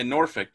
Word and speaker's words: Norfolk: Yeah, Norfolk.